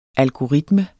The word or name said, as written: algoritme